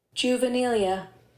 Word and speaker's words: juvenilia: Juvenilia. – Let's hear that one more time. – Juvenilia.